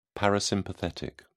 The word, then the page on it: parasympathetic: British English